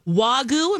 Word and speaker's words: Wagyu: Wagyu, am I saying that right? – Yes.